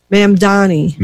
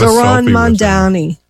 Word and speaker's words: Mamdani: Mamdani. – No, I think you said it right the first time. I got Zoran Mamdani.